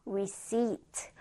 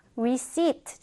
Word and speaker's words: receipt: receipt. 영수증이야. receipt. 자, 지금 선생님이 피 발음했나요? 네, 피 발음하지 않았어요. 피는 무음 처리해서 그냥 receipt라고 발음을 하게 됩니다.